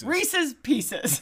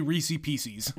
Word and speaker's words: Reese's Pieces: There is some contention about how to say it, but that is the correct way. – At least you don't say Reese's Pieces. – No, don't do that. That's wrong. So the thing about that, they were the second choice.